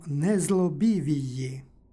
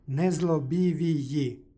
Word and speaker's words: nezlobiviji: Dakle, na kraju reči imamo sekvencu i-ji. To je teško za izgovor j između dva vokala i to j čuje se slabo. Međutim, kada se na kraju reči nađu takozvano deseterično i osmerično i, oni imaju glasovnu realizaciju i-ji. Prema tome i ovdje je takav izgovor, nezlobiviji, iako je j slabijeg.